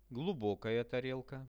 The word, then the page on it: glubokaja tarelka: Russian